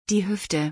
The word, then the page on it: die Hüfte: German